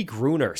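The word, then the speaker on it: Grüner: I always butcher his name, but Toby Grunier? – Is it Toby Gruner? – Toby Gruner. So it's actually pronounced as it's spelled.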